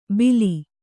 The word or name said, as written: ಬಿಲಿ